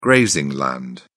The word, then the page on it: grazing: British English